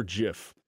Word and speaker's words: GIF: I've got a lot of You say GIF or JIF? – It's a soft G. – So you go with the GIF? – Yeah, like the peanut butter.